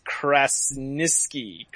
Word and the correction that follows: Krasinski: Who cares? Justin, Polish people in their last name. – Right, yeah. Justin, let's pronounce this for me. – No, you're good. – Krasinski. – Krasinski. Okay, very good.